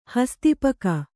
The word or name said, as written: ಹಸ್ತಿಪಕ